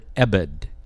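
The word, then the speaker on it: Ebed (עבד): And the Hebrew word is the word Ebed, E-B-E-D. Is its pronunciation, Ebed.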